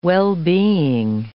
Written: well-being

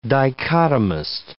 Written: dichotomist

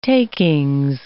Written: takings

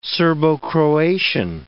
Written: Serbo-Croatian

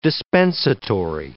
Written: dispensatory

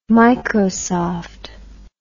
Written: microsoft

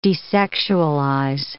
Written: desexualize

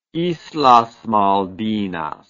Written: islas malvinas

